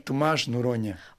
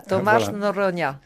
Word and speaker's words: Tomás Noronha: Tomás Noronha. J'espère bien le prononcer. – Oui, Tomás Noronha. – Voilà, Tomás Noronha, qui est historien.